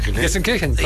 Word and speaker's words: Gelsenkirchen: Gelsenkirchen.